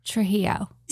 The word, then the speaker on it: Trujillo: Trujillo.